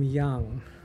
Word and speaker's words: Yang: My name is William Young, but I spell it in the Chinese way, Y-A-N-G.